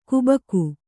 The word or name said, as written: ಗಬ್ಬಿಗೆ